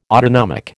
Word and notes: autonomic: American English

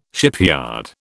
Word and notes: shipyard: British English

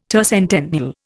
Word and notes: tercentennial: Indian

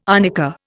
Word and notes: arnica: Indian

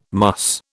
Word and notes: muss: British English

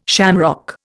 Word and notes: shamrock: Indian

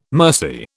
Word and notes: mercy: British English

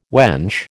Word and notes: wench: American English

